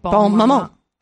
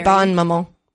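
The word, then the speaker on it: Bonne Maman: B-O-N-N-E. – Yep. – M-A-M-A-N. – Yeah. – Bon Maman. – Okay, yeah. – Great. – Raspberry. It's probably Bon Maman. Because if there's the N-N-E, you pronounce the N's.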